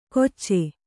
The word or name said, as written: ಗೊಚ್ಚೆ